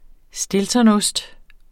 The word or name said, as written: STILTONOST